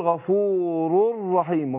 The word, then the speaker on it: غفور رحيم: ويبقى حقيقة الإدغام هنا ان نون التنوين هي اللي أدغمت في الراء، فأنا بنطق رأين مع بعض مع كون اللي أدغمنا نون التنوين في الراء بقول غفور رر يبقى أقول غفور رحيم غفور رحيم هكذا، يبقى أقول والله غفور رحيم.